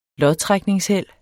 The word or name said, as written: lodtrækningsheld